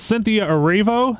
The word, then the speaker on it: Cynthia Erivo: Cynthia Erivo.